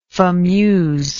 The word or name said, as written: fameuse